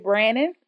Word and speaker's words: Brannan: Again, it's called Sunshine on Amazon by Joy Brandon. My last name is spelled B-R-A-N-N-A-N.